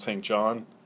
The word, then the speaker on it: St. John: You, by the way, you know it's St. John, not St. John. Everyone knows that? Okay, now you do. The way St. John is pronounced is St. John. That's the English pronunciation of St. John.